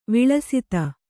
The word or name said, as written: ವಿಳಸಿತ